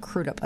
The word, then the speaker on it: Crudup: Crudup.